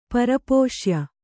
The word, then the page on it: ಪರಪೋಷ್ಯ: Kannada